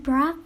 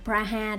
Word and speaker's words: Praha: khi đến prague um, người việt mình hay đọc là praha đó các bạn thì khi đến praha mình uh,